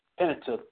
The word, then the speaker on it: Pentateuch: Forgive me if I'm saying it wrong, but that's what I can remember. But go ahead. – Oh, yeah, it's Pentateuch,